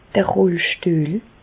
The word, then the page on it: d'r Rollstüehl: Bas-Rhin; Reichshoffen